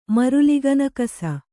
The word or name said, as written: ಮರುಲಿಗನಕಸ